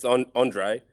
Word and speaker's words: Andree: It's Andree. I think it's French in origin,